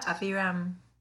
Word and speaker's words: Aviram: Yeah, Aviram.